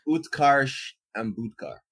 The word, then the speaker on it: Utkarsh Ambudkar: And Utkarsh Ambudkar.